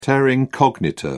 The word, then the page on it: terra incognita: British English